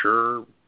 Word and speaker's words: Scherr: Christopher, I guess you pronounce his name Shrub or Sherb. S-C-H-U-R-R. Sher. Christopher Scherr, I guess you can say.